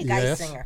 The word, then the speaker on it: Geisinger: Geisinger. – Yes. – Geisinger. Like a guy singer.